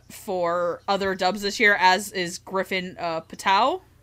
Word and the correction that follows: Puatu: I think I pronounced his name right. – Puatu. – Puatu. Griffin Puatu.